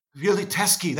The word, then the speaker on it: Poliziotteschi: Poliziotteschi, that's it. Yeah, it looks like Chetty and it's not.